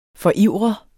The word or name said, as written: forivre